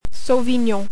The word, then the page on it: Sauvignon: French